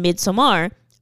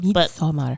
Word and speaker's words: Midsommar: I keep pronouncing it Midsommar because that's literally how it's spelled. – Midsommar. – But I don't really care. I think we're supposed to say it like we're Swedish.